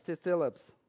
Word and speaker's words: thlipsis: T-H-L-I-P-S-I-S, Thilips.